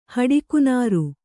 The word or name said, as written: ಹಡಿಕುನಾರು